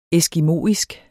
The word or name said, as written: ESKIMOISK